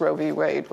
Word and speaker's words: Roe v. Wade: Yes, which all now every state makes the decision whether they're going to allow abortion or not since Roe v. Wade was shot down.